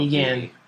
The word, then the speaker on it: Negan: Pronunciation Negan. Off of Negan. – Negan. – Knee. – Off of what? Wait. – Off of the Walking Dead wiki. That's as good as we're getting.